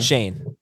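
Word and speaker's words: Schoen: I'm going to mispronounce the name. Jim Shine. – Shane. Joe Shane.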